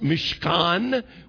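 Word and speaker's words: mishkan (מִשְׁכָּן): It's pronounced mishkan with a lot of guttural sounds to it.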